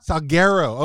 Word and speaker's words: Salguero: Did I pronounce that correctly? Jess Salguero. – Close. – Jess Salguero. Salguero. Okay. Well. – Yeah. It was good.